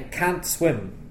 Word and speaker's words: can't: And I think it's because most native speakers don't pronounce the T at the end of the negative can't. – That's right, Craig. So when people say, for example, I can't swim.